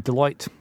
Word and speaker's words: Deloitte: Deloitte.